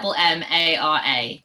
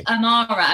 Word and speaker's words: Ammara: My name is Ammara, and people say Ammara, and I'm like, it's Ammara.